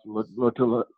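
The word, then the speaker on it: Lotulelei: he had star, uh, I think it's pronounced Lotula. – Star Lotula. Get it for me. – Correct.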